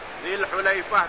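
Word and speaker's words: Al-Hulaifah (الحليفة): Al-Hulaifah tadi bacanya bi dhammil ha'il muhmalah wa fathil lam, dengan dhammah ha'u dan patah lamnya.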